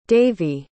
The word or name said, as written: daveigh